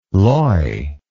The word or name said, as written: loy